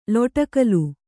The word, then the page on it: ಲೊಡಕಲು: Kannada